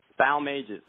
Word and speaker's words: Balmages: So the best way to say it is Bal like pal and Mages like pages. So if you say pal pages, then Balmages. – Balmages.